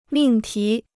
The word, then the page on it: 命题: mìng tí